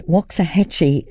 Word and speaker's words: Waxahachie: It's called Fire and the album's called Saint Cloud, and Waxahachie is spelt W-A-X-A as in Waxa. We'd say Waxa, but it's actually Waxa. Waxahachie as in Waxa, hat, and chi for cheese.